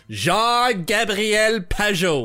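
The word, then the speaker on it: Jean-Gabriel Pageau: 5 was I, meaning me, go to great and unjustifiable lengths in order to pronounce his full name in French. Jean-Gabriel Peugeot.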